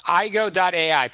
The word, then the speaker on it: AIGO: but it's AIGO.AI, and you are a leader in the space. Welcome to the program. – Yes, thank you. It's IGO.AI. – IGO. Okay, perfect. IGO.AI.